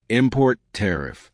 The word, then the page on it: import tariff: American English